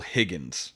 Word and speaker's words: Higgins: His name is Kyle Higgries, I think it is pronounced that way. Well, let me make sure that's how you pronounce it, so I'm not a huge D-bag. Kyle Higgins. Written by Kyle Higgins and Ryan Parrott.